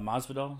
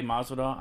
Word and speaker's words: Masvidal: I tell you this, I did like Mas, uh, Masvidal. Is that how you say his name? Yeah, Jorge. – Yeah, Jorge Masvidal.